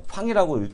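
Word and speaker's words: FANG: 그래서 기본적으로 미국 시장에 대해서는 조금 바닥권에 진입했다는 그런 부분을 말씀을 드리고 우리가 가장 많이 알고 있는 종목에 대해서 말씀을 드릴 건데 FANG라는 얘기 있습니다. 팡이라고 읽기도 하고요.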